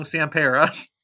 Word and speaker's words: Sampera: Anyway, it's CJ, probably going to pronounce his last name wrong, Sampera.